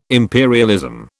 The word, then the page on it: imperialism: British English